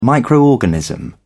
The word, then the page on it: microorganism: British English